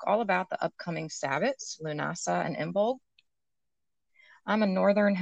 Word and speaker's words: Lughnasadh: Lunasa is spelled nothing like it sounds. It's Lughnasadh, but it's Lunasa.